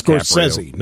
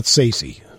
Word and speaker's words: Scorsese: And that's the new Martin Scorsese movie with Leonardo DiCaprio. It's Scorsese, not Sacy.